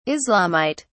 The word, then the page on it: islamite: British English